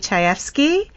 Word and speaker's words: Chayefsky: And it's his friend, Patty Chayefsky. Ooh, I finally said it right.